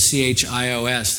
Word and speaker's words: Chios: It's spelled C H I O S. That's pronounced Chios um, there.